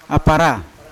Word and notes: apara: Yawa; Unat

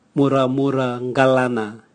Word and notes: muramura ngalana: Raga

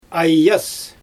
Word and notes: ajys: Xyzyl

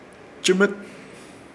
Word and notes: Chɨmɨt: Ishir Chamacoco

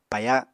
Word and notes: paya: Apatani

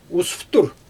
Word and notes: usftur: Xyzyl

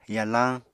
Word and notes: yalañ: Apatani

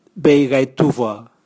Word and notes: beigaituvwa: Raga

